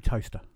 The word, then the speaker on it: Towcester: No, I went through Toaster. – Yeah. – Which, for people who are not from Northamptonshire, is not a thing that browns your bread. It's small town. – It's spelt differently, isn't it? – Yeah.